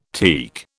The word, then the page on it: teak: British English